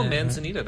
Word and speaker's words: Manzanita: Manzanita, or maybe I'm pronouncing it. No, Manzanita, that's correct.